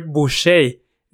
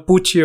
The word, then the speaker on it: Boucher: Pues es que además su nombre es Christopher, se pronuncia Christopher Boucher, no Pucci o Bucci, como lo dice ese güey al final del video.